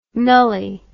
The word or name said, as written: nully